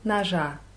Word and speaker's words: ножа: Also Udruga Njet Noscha. – Mhm. У друга нет ножа. Ну, die Betonung war ein bisschen falsch. Ножа auf A.